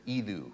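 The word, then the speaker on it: idu (ἰδού): In the Greek, it's the word idu. It's like edu, but idu, because that's how they pronounce it in Greek.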